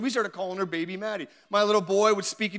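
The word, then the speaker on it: Mahdi: She goes, Maddie. I'm like, oh, I like that. She goes, no, but it's spelt different. She goes, It's M A H D I. I'm like, Oh, I like that. It sounds normal, but it's spelled different, right?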